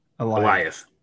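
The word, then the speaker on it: Alioth: Is it Azioth or something? – Eliath.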